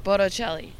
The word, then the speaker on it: Bocelli: Wait, I think you said his name wrong. Wait, how do you say it? – Andreas Bocelli. – How are you saying the last name? – Bocelli. – Bocelli? It's that? – Yeah. – Oh, I did not know that.